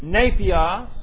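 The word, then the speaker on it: Nepios (νήπιος): Now this word here is nepios, N-E-P-I-O-S. Nepios. That's a long E here.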